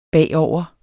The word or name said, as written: bagover